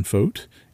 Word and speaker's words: Fogt: Who is Ben Fote? And how do you say my last name? So obviously I'm Ben Fote, and that's how you say my name. It's spelled F-O-G-T. I used to tell folks how to spell my last name. Fogt, F-O-G-T.